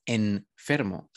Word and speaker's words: enfermo: Dices, eso me suena raro, porque yo no digo enfermo, sino enfermo, es una M, no una N, pero se, ajá, escribe como una N.